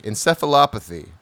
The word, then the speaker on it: encephalopathy: Encephalopathy. – I think you said it right the second time.